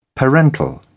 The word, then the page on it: parental: British English